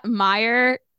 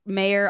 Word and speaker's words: Meyer: Meyer. I get Mayor all the time.